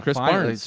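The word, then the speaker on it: Krys Barnes: He's playing well enough that I said his name correctly this week. I now know his name, Chris Barnes. – Seriously, the amount of times you called him Burns, so disrespectful.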